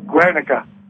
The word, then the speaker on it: Guernica: Guernica.